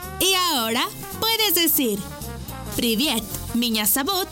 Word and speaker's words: Меня зовут: Меня зовут. Por ejemplo, Меня зовут. Esta palabra se escribe Меня. M. I. Ñ. A. Меня. Зовут. Z. A. V. U. T. Зовут.